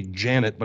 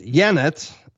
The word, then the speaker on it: Yanet: So the first one, and I'm going to spell it too, because it sounds like it's, should be Janet, but it's Yanet. Y-A-N-E-T. – C-A-R-D-I-N-A-L. – So it's going to be bird. – Yanet Garcia. Y-A-N-E-T.